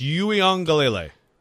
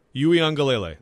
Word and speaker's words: Uiagalelei: But it's Uiagalelei. Uiagalelei. – Uiagalelei.